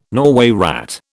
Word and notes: Norway rat: British English

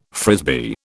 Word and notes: Frisbee: British English

